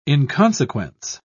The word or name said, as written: inconsequence